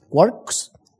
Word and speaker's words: quarks: Los quarks, esto se pronuncia como si aquí hubiese una O, quarks, y los leptones.